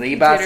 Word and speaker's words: Ribas: Amanda Ribas. I don't want to... The pronunciation, you know? – I think Ribas, you did it right. Ribas.